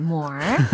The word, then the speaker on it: moor: Oh, she met him at a moor. 우리 지난번에 배웠던 moor. more 요거 예, 황무지에서 그를 만났어요. 예, 미국 발음 more. – Yeah, more.